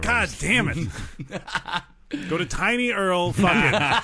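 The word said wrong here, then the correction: TinyURL: I think that's supposed to be pronounced Tiny URL.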